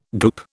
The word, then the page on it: goop: British English